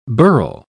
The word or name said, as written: birle